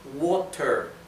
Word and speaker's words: water: La palabra water. ¿Bien? La T, que está entre medio de dos vocales, se pronuncia casi como una R del español.